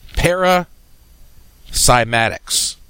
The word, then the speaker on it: Paracymatics: chapter 2 of this podcast, Paracymatics. Now, what is that? First off, let me spell it for you. P-A-R-A hyphen C-Y-M-A-T-I-C-S.